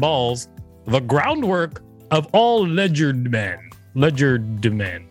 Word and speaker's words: legerdemain: Legerdemain is skillful use of one's hands when performing conjuring tricks. – Oh. – It is pronounced legerdemain. All right. I was saying it right.